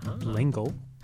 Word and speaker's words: L'Engle: That's a pronunciation from her granddaughter. – L'Engle. – So although it is a French name, I think they've Americanized the pronunciation.